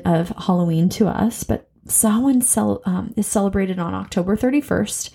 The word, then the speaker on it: Samhain: So a lot of the word Samhain looks like you say Samhain, but it's actually pronounced Samhain.